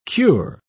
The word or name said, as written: cure